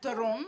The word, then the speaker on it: Theron: Yeah, Theron, that's how you say it.